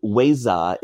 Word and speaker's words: Weza: And so Weza is actually the shortened form, the Burmese pronunciation of the shortened form Wezadara, Vijadara, which means, can, in the dictionary, it's like caster of spells or master of spells, esoteric wisdom.